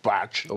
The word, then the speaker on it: Putgen: Putgen, also englisch gesprochen, Patschen.